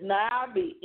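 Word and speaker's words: navi (נביא): The Hebrew word for prophet is navi. I may not pronounce that right. It's navi, N-A-A-B-I, from the verb nava.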